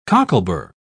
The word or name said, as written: cocklebur